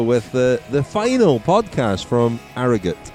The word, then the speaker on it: Harrogate: Apparently we've been saying Harrogate wrong, and a listener, a friend of the podcast who was on our bike ride the other day, gave us the correct pronunciation. Let's just hear what it is.